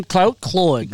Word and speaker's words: Cloyd: Cloyd. – I should know. It's sort of like Floyd, but it's Cloyd.